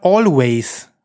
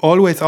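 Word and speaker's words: Alvvays: Also, das ist das dritte Album dieser, äh, dieses Trios, die äh, äh sich, ich glaube, es wird Always ausgesprochen, schreibt sich mit Doppel-V und A-Y.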